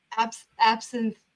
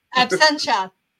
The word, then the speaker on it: Absentia: This Absinthia. – Absentia.